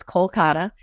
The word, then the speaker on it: Kolkata: In English, the Indian way to pronounce it is Kolkata.